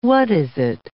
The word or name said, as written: what-is-it